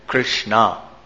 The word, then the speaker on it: Krishna: Yamuna Devi is dark, her waters are dark, and as I mentioned yesterday, therefore she is called Krishna, the long A, as is Draupadi, because she is also dark complexioned.